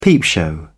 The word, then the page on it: peepshow: British English